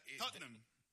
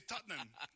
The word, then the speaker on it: Tottenham: Tottenham. Son dos sílabas. – Ajá. Es difícil, ¿no? – Nosotros los británicos. Pero... – ¿Solo dos sílabas? – Sí, Tottenham.